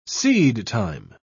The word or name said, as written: seedtime